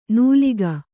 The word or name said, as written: ನೂಲಿಗ